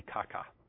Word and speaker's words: kaka: And if it's done just right, like this, the word harm actually in the Greek, if you wanted to pronounce it, would be kaka.